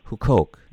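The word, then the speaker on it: Huqoq: I just have one final question, and I think it's a biggie for you because it really has to do with Hukok, I think, is how it's best mm-hmm. pronounced. Yep, good.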